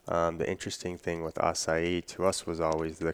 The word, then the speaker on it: acai: Is that something, I mean, is Asai the one? Is it the chosen one? Am I saying it right? – Acai, yeah.